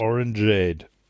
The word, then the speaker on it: Orangeade: Wie Lemonade, nur mit Orange. Orangeade. – Orangeade, ja.